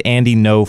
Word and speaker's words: Ngo: If you just Google Protect Andy No Fund, that's Andy NGO. That's how you pronounce it, No Fund. – So he spells his name NGO, but you're giving me a hard time over Cato. Okay. Okay. Whatever. Just want to be clear. – But I pronounced it correctly. That's the whole point there. – It's not to go.